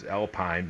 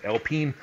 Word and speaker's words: Alpine: They're going to go with Alpine, which to many Americans is Alpine, but it's pronounced Alpine.